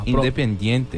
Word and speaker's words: Independiente: Independiente, é sim? – Gostei. – Gostou? É isso aí.